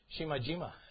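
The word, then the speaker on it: Shimajima (島々): And that's pronounced Shima. But it's plural, so it's Shima, Shimajima. Shimajima.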